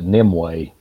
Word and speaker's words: Nimue: And so Arthur was forced to surrender to Nimu. How you spell that? – I've heard somebody pronounce it Nimue. – So. Nimue. There it is.